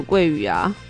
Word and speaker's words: 鳜鱼: 韩文字儿，他说是松鼠鳜鱼啊，还是松鼠桂鱼啊？我查一下你这个字儿怎么读。桂鱼，松鼠桂鱼哦，松鼠桂鱼是哪里的？